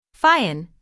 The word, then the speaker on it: Fion: Fion, pronounce fion to rhyme with tune, got his first training among women.